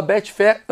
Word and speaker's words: Betfair: Lembra de seguir a Betfair, arroba Betfair, underline, net.